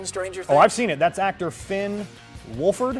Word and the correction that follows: Wolfhard: Am I saying that right? I, if I were him, I'd go with Wolf. – Wolfhard. But, you know. All right, Finn Wolfhard. Finn Wolfhard.